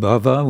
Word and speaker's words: Bhava: And Bhava, with a short A, means existence, so it kind of comes to mean the material life, material existence.